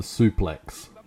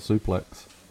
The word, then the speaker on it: suplex: Its proper pronunciation is suple. – Because obviously it's got the X and it's, I guess, what's that, like a French word, I guess? – Well, I mean, the pronunciation sounds French. – Yeah. But, you know, an Americanization obviously takes place and people just call it suplex.